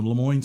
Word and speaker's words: LeMoyne: the LeMoyne Center for the Visual Arts. – Correct. – I said that properly, yes.